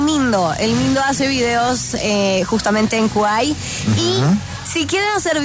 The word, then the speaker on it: Kwai: Para que la descarguen, se escribe K-W-A-I latina. – Ajá. – K-W-A-I latina. – Sí. – Se pronuncia Kwai.